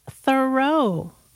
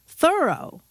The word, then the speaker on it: Thoreau: All this time, I thought his name was pronounced Thoreau, but no. – How is it pronounced then? – Thorough. – Like I thoroughly did it. – Yeah, or I'm thoroughly surprised that it's pronounced that way.